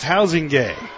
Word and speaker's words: Huizenga: It looks like Huizenga, but it's pronounced Housingay.